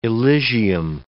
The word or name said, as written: Elysium